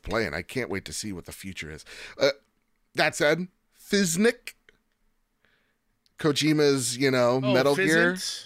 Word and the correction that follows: Physint: It just I think you added a K sound in there. – What, yeah. How do you say it? – Fizzint. P-H-Y-S-I-N-T. – Okay, yeah, Fizzint.